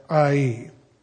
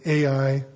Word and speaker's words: Ai: And what we're going to see in chapter 8 is how Israel bounces back from their defeat at Ai. Maybe you're expecting me to say Ai. That's the popular pronunciation, but it's really Ai.